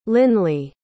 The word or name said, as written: lynley